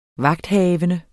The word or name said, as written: vagthavende